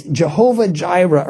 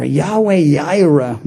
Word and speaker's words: Yahweh Yireh: It's Jehovah Jireh or Yahweh Jireh or Yahweh Yireh more probably accurately.